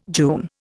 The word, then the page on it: June: Indian